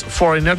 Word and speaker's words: Foreigner: Y claro, es Foreigner, tal cual lo dice Nico. La G no se pronuncia, ya que la combinación GN, la G queda muda, como design.